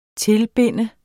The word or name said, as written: tilbinde